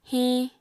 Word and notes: hiñ: Apatani

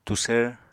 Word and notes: tusvr: Apatani

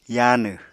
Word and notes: yaanw: Apatani

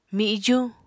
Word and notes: mijuñ: Apatani

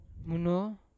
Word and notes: muno: Yawa

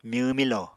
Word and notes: myw-milo: Apatani

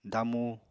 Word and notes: damu: apatani

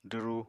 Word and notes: dwru: apatani

